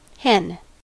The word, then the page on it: hen: American English